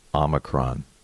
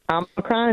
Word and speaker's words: Omicron: Omicron instead of Omicron? – I looked it up. – When There's this thing called Google, and I looked up the pronunciation of the Greek alphabet. Yeah, I think it's Omicron.